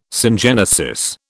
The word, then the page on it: syngenesis: British English